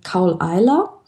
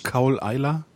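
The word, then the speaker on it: Caol Ila: Und was ich hier jetzt habe, das ist ein Kaul Eiler.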